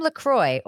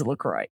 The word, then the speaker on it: LaCroix: I imagine just because I think of you with every moment, but I think of you anytime someone mispronounces LaCroix. It literally says on the website, rhymes with enjoy.